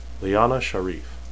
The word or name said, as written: leane